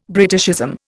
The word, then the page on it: Britishism: Indian